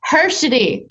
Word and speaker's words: Hershied: Hershied. Okay. – Nobody ever says it right. When my husband teaches people to say the candy bar, the letter D, Hershied.